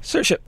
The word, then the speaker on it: Saoirse: Good morning, Saoirse.